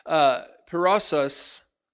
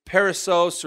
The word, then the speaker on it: perissos: That Greek word there is actually perossos. I probably said that wrong. I think it's perissos or perossos. It's P-E-R-I-S-S-O-S.